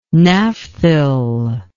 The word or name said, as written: naphthyl